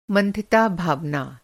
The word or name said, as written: मंथिता